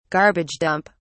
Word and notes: garbage dump: British English

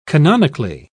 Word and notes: canonically: British English

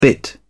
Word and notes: bit: British English

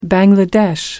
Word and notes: bangladesh: American English

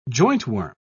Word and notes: jointworm: British English

